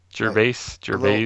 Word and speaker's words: Gervais: It might be Gervais. – Gervais. – A little softer S. It's closer to a Z, but I don't know.